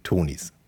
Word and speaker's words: Tonies: Du bist also Chief Product Officer für die Tonis. Habe ich das richtig gesagt? – Das ist total richtig.